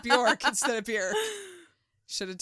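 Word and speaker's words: Björk: Yeah, it's Bjork. – It rhymes with jerk.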